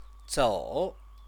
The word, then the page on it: zou: Chinese